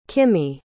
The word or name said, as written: kimiye